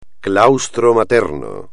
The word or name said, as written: claustro materno